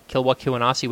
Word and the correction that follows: Kisiwani: whatever the Kisiwani.